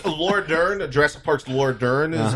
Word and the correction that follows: Laura Dern: Jurassic Park's Laura Dern.